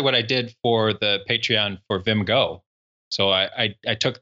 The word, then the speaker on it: Patreon: Florin in the GoTime FM channel also just listed as Patreon. How do you pronounce it? Patreon? – Patreon.